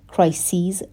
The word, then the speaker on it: crises: Crises جمع crisis هستش. Crisis C-R-I-S-I-S به معنای بحران هست و به این شکل تلفظ میشه crisis ولی وقتی میخواییم جمع ببندیمش اسپل کلمه عوض میشه C-R-I-S-E-S و تلفظش هم به این شکل میشه crises hostage crises hostage به معنای گروگانگیری هستش hostage crises یعنی بحرانهای گروگانگیری and diplomatic compromises Compromise به معنای مصالحه هست.